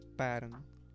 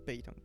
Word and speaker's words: Patton: Sí, se pronuncia Patton y no Peyton, como le decía yo.